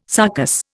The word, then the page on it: sarcous: Indian